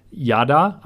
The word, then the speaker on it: yada: The Hebrew word for know is actually yada. I think I'm saying that right. Y-A-D-A, yada, which to me sounds like ta-da.